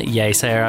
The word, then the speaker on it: Yeasayer: Yes Air, I would say. – Yes Air. But it's spelled yeah.